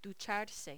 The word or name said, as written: ducharse